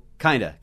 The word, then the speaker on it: kind of: Note que o kind of pronunciado no inglês natural é o kinda. Kind of, o O-F, of. Pode ficar kind of, U-V, como se fosse o som de U-V.